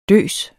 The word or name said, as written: døs